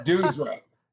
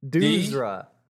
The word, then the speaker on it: Duuzra: Duuzra. Duuzra. D U U Z R A.